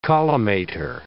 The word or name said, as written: collimator